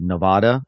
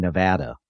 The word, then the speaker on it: Nevada: People always say Nevada, and apparently that's not right. You're supposed to say Nevada. – Nevada.